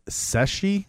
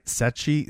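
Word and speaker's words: Ceschi: It looks like it's Sechi. It's C-E-S-C-H-I, Sechi, Sechi.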